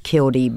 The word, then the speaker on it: killdeer: killdee bird. And it's spelled killdeer. – Yeah. – But we say killdee where I'm from.